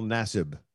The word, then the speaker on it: Nassib: No, it's Carl Nassib.